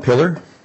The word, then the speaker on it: Pillar: Yes, my name is Ryan Pillar. Last name is spelled P as in Paul, I-L-L-A-R, first name R-Y-A-N.